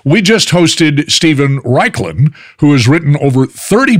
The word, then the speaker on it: Raichlen: And it's spelled funny. It's R-A-I-C-H-L-E-N, but it's pronounced Raichlen.